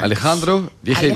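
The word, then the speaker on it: Alejandro Vigil: Alejandro Virgil, je ne sais pas si je le prononce bien. – Hein. Alejandro Virgil. – Alejandro Virgil.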